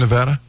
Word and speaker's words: Nevada: And by the way, did you see anything peculiar in Nevada? – Certainly. Yeah, I did. Okay, so Nevada, which you're saying it correctly, and I got corrected constantly because I used to say Nevada.